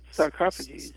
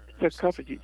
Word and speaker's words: sarcophagus: They're called, I never said it right. I think that sarcophages. – Sarcophagus.